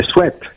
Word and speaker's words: sweat: De Mounka qui c'est un suite en capuche. – Un sweat.